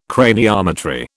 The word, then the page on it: craniometry: British English